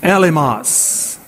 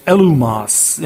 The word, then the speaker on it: Elymas: He rebuked Elymas, how we pronounce it here in the English, Elumas in the Greek.